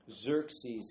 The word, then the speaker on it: Xerxes: Xerxes, his name is spelled X-E-R-X-E-S. Kind of sounds like a coffee machine. Xerxes, X-E-R-X-E-S.